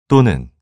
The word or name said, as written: 또는